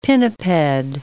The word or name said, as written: pinniped